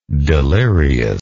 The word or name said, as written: deliriousness